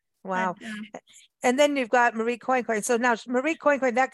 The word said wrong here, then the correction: Coincoin: Marie Therese Quanquan was born.